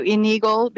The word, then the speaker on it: Enigl: Well, that's the actual correct way to say it, but I switched it to Enigl because people seem to have an easier time with it.